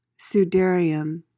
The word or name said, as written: sudarium